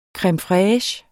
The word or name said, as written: creme